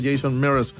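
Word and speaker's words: Jason Mraz: Pero es Jameson M-R-A-Z, pero se pronuncia Jameson Mears, el invitado en el especial musical ahí del Trasnoche.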